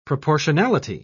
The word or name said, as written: proportionality